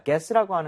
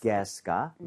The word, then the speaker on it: guess: 자, guess라고 하는 것은 추측하다, 맞춰보다 라는 뜻입니다. 여기서는 어떻게 보면 유가 묵음이라고 생각을 해야 돼요. 그래서 구에 이렇게 가지가 않죠. 무조건 guess. – Guess. – guess가 맞는 발음이고요.